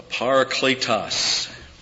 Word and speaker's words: parakletos: But a parakletos. It's a long E. Parakletos.